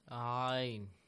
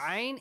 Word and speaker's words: ayn: Yes, Ayn. – Yeah, Hamza has a fatha sound. E. But yes. Ayn is from the top part of your throat.